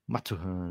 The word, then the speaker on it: Mathurin: Guilherme, aí tivemos a partir daí, no top 10, Benedict Mathurin, canadense, Shadon Sharp. Você abandonou nossa pronúncia? – Maturhan, né? Benedict oh. Maturhan, canadense.